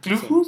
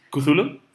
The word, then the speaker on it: Cthulhu: ¿Cluju se pronuncia? ¿Cómo lo pronuncia? – ¿De qué estás hablando? – De lo que hizo Lovecraft. – ¿Cthulhu? – Ah, Cthulhu. Bueno, no.